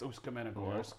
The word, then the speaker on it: Ust-Kamenogorsk: Ust-Kamenogorsk. – Yeah, it's Ust-Kamenogorsk. – Nice pronunciation.